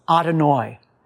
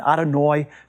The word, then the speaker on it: Adonai: And in the Siddur, God's name in Hebrew is pronounced Adonai. Now, Adonai is used approximately 300 times in the Hebrew Bible. It simply means Lord or Master. The reason that Jewish people use this title for God, Adonai,